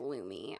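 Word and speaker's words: Lume: I think it's pronounced Lumi. – It is. It is. – It is. It is. – It is. I checked it out.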